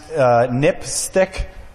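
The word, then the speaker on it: NPSTC: There are some recommendations by the National Public Safety Telecommunications Commission, which is pronounced NIPSTIC, which is kind of an odd acronym name.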